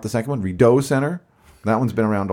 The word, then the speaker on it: Rideau: No, it's It's Rideau. From the French word Rideau,